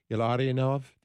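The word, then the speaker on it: Illarionov: Let me make sure I get your name right. Ilarinov, did I get that right? – Correct, yes.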